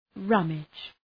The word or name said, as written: rummage